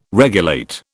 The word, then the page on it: regulate: British English